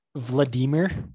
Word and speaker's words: Vladimir: But we were going to talk about how Vladimir Putin, how's my Russian? Was that okay? – It's Vladimir, actually. The accent is on the first okay.